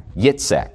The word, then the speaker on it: Yitzhak: Like laughter, Isaac, Yitzhak, that's the way you pronounce it. Yitzhak, Yitzhak, Yitzhak, Yitzhak, Yitzhak. It actually sounds like you're laughing.